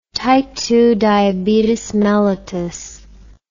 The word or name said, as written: type II diabetes mellitus